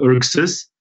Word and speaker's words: Erxes: Pronounce the name of the company again for me and then spell it, because it's very difficult. – Okay. It's called Erxes, E-R-X-E-S.